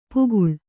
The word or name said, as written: ಪಾಕಳ